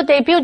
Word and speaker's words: debut: computer 这个字的重音在第二个音节。或者是 when you say the word debut, accent the last syllable. 当你说 debut 这个字的时候，重音要放在最后一个音节。